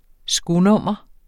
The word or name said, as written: skonummer